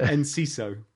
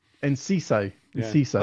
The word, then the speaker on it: Enciso: Is it in Cisco? – What, Thong Song? – That's Cisco, no, isn't it? – Enciso. – Enciso. – I think that's how you say it. That's how they say it on the radio anyway. Yeah. – So the forwards are bad and potentially it could open up a 4-5-1 because actually that's an argument against Jota.